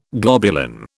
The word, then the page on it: globulin: British English